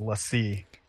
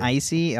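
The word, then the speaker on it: l'Cie: That word is Lassie. – Lassie. Not Icy. Oh, it's Lassie.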